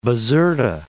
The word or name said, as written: Bizerta